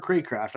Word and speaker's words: Cracraft: It is Cracraft.